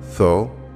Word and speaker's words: Tho: Important among these are tho, m, me, and er. Tho is pronounced like throw without the r.